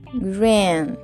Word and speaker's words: green: Verde se pronuncia con i, green, rin Vamos a hacer un sonido. Vas a colocar la lengua en la parte de arriba del paladar y detrás de tus dientes superiores. rin Y va a ser un sonido así como medio nasal.